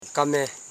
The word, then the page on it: kame: ka.me; Koro